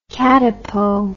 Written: katipo

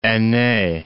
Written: ainee

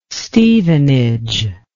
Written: Stevenage

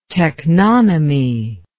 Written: teknonymy

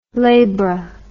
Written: labra

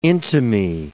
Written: intimae

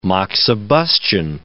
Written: moxibustion

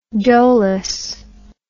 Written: doughless

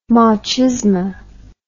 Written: machisma

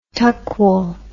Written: Tuckwell

